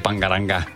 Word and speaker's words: Bangaranga: Bangalanga? – Bangaranga. – Bangaranga? – But it's pronounced like Bangaranga.